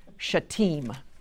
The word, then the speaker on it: Shittim: The Jordan River was the boundary to be crossed into, and I looked up, and it's actually that city, S-H-I-T-T-I-M, is Shittim, is how it's pronounced.